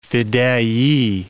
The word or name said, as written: fedayee